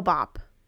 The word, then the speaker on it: Hale-Bopp: Hale, I don't know if it's Bop. I think it's Hale Bop. It's Hale-BOPP, so I'm assuming it's pronounced Bop.